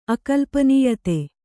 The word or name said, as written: ಅಕಲ್ಪನೀಯತೆ